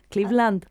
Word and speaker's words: Cleveland: Ethel Gilmour nació en Cleveland, ¿cómo se dice? Cleveland.